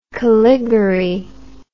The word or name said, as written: caligari